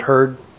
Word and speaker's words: Herd: My name is David Hurd. My name is spelled H-E-R-D.